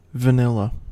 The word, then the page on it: vanilla: American English